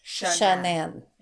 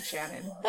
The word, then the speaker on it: Shanann: Her name is Shanann. – Yeah. I know. At first I was saying Shannon.